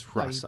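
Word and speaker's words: Hrossa: The H is not silent. It's Hrasa.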